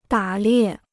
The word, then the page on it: 打猎: dǎ liè